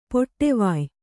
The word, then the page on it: ಪಡುವಾಯ್: kannada